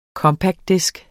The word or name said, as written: compactdisk